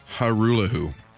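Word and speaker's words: Hajrullahu: Hajrulahu. – Hajrulahu. – Hajrulahu. – Yeah, so the J is silent? – And the J is silent in the last name of the kicker there.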